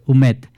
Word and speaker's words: Humet: Es de Joan Baptista, no sé cómo se dice, Humet. – Humet, que es Clara, sí.